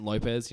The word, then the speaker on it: Lopez: Is that a soft L? Lopez. – I'm pretty sure it's... I just pronounce it Lopez, you know, just phonetically. – Lopez. – Yeah. – You mean just normally? – Just normally, yeah.